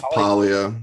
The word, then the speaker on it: Paglia: So Camille, do you pronounce it Paglia? I never got this straight. – It's Paglia.